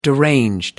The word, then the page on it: deranged: American English